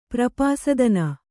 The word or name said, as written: ಪ್ರಪಾಸದನ